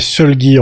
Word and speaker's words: Solgi: Et puis tu voulais nous parler de musique aussi avec Solgi, c'est comme ça que ça se prononce? – Ouais, Solgi en fait. – Solgi, ok. – Solgi chez nous, c'est comme ça qu'on dit, voilà.